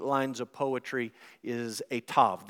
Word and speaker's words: Tav: In your Bible translation, there's probably a heading and it says T-A-W, but the way that you pronounce it in Hebrew would be Tav.